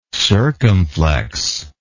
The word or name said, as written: circumflexion